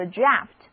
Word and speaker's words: draft: Outline 也有一个近义词叫做 draft， D-R-A-F-T，意思是草稿、草案。注意字母 A 在这里的读音是长的 R， draft。